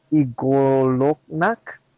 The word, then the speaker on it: Y'golonac: Uh, uh, how you say it is Y G O L O N A C. Kind of Igoloknak? – Got it. Oh, you got Something like that. Igolak, yeah. Y'golonac.